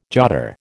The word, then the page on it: jotter: American English